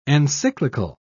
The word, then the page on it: encyclical: British English